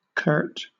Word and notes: curt: British English

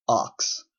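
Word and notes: ox: Canadian English

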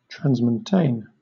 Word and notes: transmontane: British English

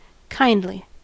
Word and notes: kindly: American English

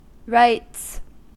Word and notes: rites: American English

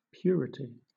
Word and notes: purity: British English

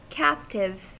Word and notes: captive: American English

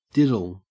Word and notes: diddle: Australian English

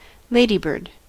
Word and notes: ladybird: American English

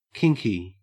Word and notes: kinky: Australian English

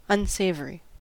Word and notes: unsavory: American English

